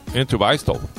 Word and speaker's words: Entwistle: Em 44, baixista britânico John Entwistle. Essa pronúncia faz parte da banda The Who, não sei se foi considerado o melhor baixista. – Entwistle.